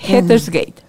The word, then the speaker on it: Hetterschijt: Ustedes pueden encontrarla en Facebook como Tazo aquí tenía yo, pues, es que tu apellido se pronuncia Hetersgate. – Hetersgate.